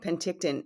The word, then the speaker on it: Penticton: And I'm hoping I'm going to uh, pronounce this right Penticton? Penticton? – That's exactly right, Penticton.